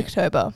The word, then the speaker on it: Spooktober: Spookytober. – I don't know. – Cut the Y. – Oh, okay. – Spooktober.